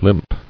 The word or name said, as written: Limper